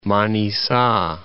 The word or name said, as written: Manissa